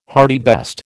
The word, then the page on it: hartebeest: American English